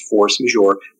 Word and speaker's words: force majeure: Force majeure is French, though it is actually pronounced force majeure.